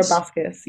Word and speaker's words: Buskes: Laura Buskes.